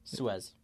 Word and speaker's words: Suez: Suez.